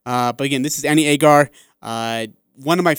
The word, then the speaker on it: Agar: This is our interview with Annie, and I call her Agnar. That's my bad. Sorry. I tell you, I kept calling her Agnar, and then before our interview started, I was like, hey, how do you say her name? She's like, oh, it's Agar.